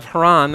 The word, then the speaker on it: Haran: Haran, the son who died, is pronounced the exact same as Haran, the city in which they settle in.